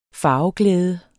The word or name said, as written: farveglæde